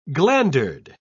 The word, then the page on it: glandered: British English